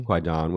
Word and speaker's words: Kwaidan: Is it Kwaidan? – Kwaidan, which I guess is an archaic Japanese word meaning ghost.